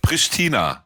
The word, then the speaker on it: Pristina: Pristina. – Pristina, sag ich doch. – Wie Christina, nur Pristina.